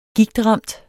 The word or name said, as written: gigtramt